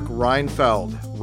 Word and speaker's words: Reinfeld: My guest today on the show is Mark Reinfeld, rhymes with Seinfeld, a world-renowned, award-winning vegan chef, activist, teacher, all kinds of things, helping us to save ourselves from ourselves.